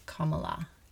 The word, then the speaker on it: Kamala: On my radar, I mean, um, after the inauguration, Kamala's sorry, Kam- Kamala. Yeah, Kamala. That's how you pronounce it. I double check today.